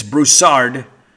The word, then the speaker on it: Broussard: Number 5, this is a great song, and I don't know how many of you know it, but Mark Broussard, and it is Broussard, emphasis on the R, but it's Baton Rouge.